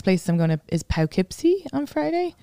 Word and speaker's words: Poughkeepsie: Poughkeepsie.